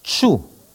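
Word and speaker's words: ĉu: Ces circonflexes se prononcent tch, comme dans tchèque. Exemple, tchou, est-ce que.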